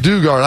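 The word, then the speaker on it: Dugard: You mispronounced it, and that's what caught me off guard. I did read that book, Duggard. – Duggard. I apologize.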